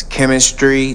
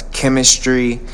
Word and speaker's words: Kymistry: Her name is Chemistry. That's spelled K-Y-M-I-S-T-R-Y. That's Chemistry.